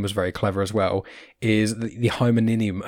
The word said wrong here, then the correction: hymenium: I can't even pronounce it. – Oh yes. Hymen. Hymen, hymenium, hymenium, hymenium, hy- hymen, and then uh, neum, neum. So it's like um, a flower, and obviously hymen.